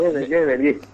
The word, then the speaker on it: Gebel: Gebel. – No, Gebel. – Quiero pronunciarlo Gebel, bien porque no dice, si le agregamos una S.